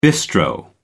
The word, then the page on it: bistro: American English